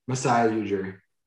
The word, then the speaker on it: Masai Ujiri: Masai Ujiri.